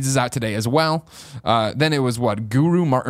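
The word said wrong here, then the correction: Garou: That's not how you say it. Guru Mark of the Wolves is what I wrote down, but I think that's it's Garu, I think. – Garu Mark of the Wolves.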